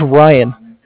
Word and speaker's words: Ryan: It was Ryan. – Spell it. – It's R-Y-A-N.